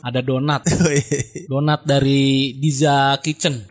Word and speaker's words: Deeza Kitchen: Oh, Diza Kitchen. – Iya, ejaannya DEEZA Kitchen. Kitchen-nya bahasa Inggris ya.